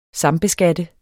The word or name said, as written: sambeskatte